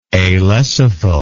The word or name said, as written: alecithal